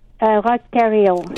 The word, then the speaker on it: Roch Thériault: Roch Thériault.